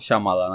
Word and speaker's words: Shyamalan: Cred că Shyamalan se Shyam- pronunță. – Shyamalan? Da. Shyamalan, așa.